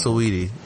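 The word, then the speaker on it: Saweetie: That's how you say her name? – Yeah, it's Sweetie. – I thought it was Sway T.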